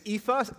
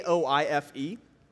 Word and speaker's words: Aoife: Her name is Aoife. Don't try to spell it. It's Irish. I'll spoil it. It's Aoife, which is quite unique.